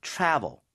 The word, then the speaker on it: travel: 이 trouble, travel, 발음이 다르죠? – Yeah, that's right. 여기는 travel. Travel. – V이기 때문에 입술이 붙어서도 안 되고, 그리고 입이 좀 많이 벌려진 것 같으네요.